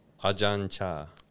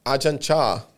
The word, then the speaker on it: Ajahn Chah: Ajahn Chah. – Ajahn Chah.